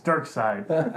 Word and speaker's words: Darkseid: Okay, I'll throw up on you if you pronounce it Darkseid. It's Darkseid. I know him because I peeped a lot of the Justice League cartoons, and he was like always the big villain.